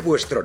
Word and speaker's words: vuestro: Consideradme vuestro nuevo jefe. – Tutaj uwaga, Buestro pisze się przez V, Fuestro, nie jest używane w latynoskim hiszpańskim.